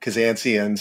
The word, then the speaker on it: Kazanciyan: One thing that comes to mind is how to pronounce my last name, which I often get a lot, Kazanciyan.